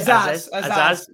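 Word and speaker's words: Azaz: The second is Finn Azzez. Have I said that right? – Azzez.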